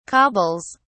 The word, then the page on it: cobbles: British English